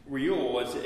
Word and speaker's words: Reuel: Reuel is how they pronounce his name here. Some other translations use a different name for Moses' future father-in-law.